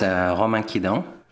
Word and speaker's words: Romain Quidant: So my name is Romain Quidant. – He definitely pronounces his name differently than you do.